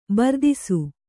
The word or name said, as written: ಬರ್ದಿಸು